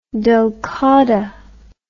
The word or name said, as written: dhulqadah